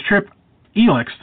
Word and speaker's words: Trip Elix: But my guest tonight is Trip Elix.